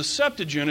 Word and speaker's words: Septuagint: There's a Hebrew translation called the Masoretic Text, and there's a Greek translation called the Septuagint, or the Septuagint, if you want to pronounce it correctly.